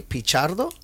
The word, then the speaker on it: Pichardo: We also have Stephanie Pichardo.